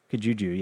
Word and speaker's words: Kijuju: It's Chris Redfield back and Shiva Alomar in the area of, I'm hopefully not saying this wrong, is it Kijuju? – Kijuju, yeah.